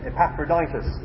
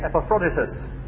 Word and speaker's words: Epaphroditus: Philippians 2 and verse 25, where we read about, well, there's dispute as to how you pronounce his name. I always used to say Epaphroditus. Other people tell me it's Epaphroditus.